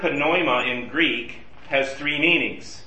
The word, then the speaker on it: pneuma: It's in Greek it's spelled P-N-E-U-M-A. They have no silent letters. So if you're a Greek speaker, you pronounce all the letters. So you say pneuma, versus in English we would just say pneuma, or pneumatic, or pneumonia.